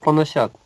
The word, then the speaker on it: поносят: Преданные Господа так терпеливы, что никогда не склонны мстить за себя, даже если их поносят, обманывают, проклинают, беспокоят, презирают или даже убивают. – Мне кажется, что слово «поносят» читается как «поносят».